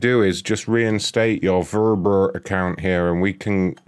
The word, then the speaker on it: VRBO: It's Verber. Anyway, it's pronounced Verber.